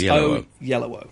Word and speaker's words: Oyelowo: His surname, a yellow O, is O at the beginning, O at the end, and yellow in the middle. So it's O-Yellow-O.